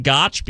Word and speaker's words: Goch: Always on X at Jason Gotch is my X handle, G-O-C-H, on the last name spelling, last part of that X handle. Sounds like there should be a T in there. There's no T. It's just the way it's spelled, and it's just the way it sounds. It sounds like Gotch because it is Gotch, but there is no T. So at Jason Gotch, G-O-C-H, on the last name spelling for my X handle.